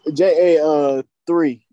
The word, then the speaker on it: JA3: J-A-3. – Yeah, J-A-3. Okay, so yeah. That's how you like to pronounce it, J-A-3? – You say J or J-A-3. It's kind of like black.